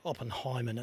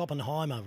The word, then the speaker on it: Oppenheimer: And if you're watching a movie, get the name right, not like Rooch, Oppenheimer. It's Oppenheimer, Rooch.